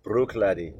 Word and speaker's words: Bruichladdich: Bruichladdich, as it says.